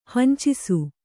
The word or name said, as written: ಹಿಂಚಿಸು